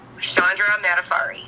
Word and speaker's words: Chandra Madafferi: Chandra Madafferi.